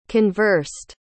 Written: conversed

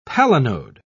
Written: palinode

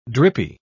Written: drippy

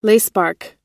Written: lacebark

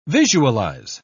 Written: visualize

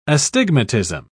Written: astigmatism

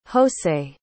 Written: hosei

